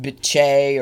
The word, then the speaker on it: Bitche: It's pronounced Bitche or whatever.